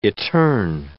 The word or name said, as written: eterne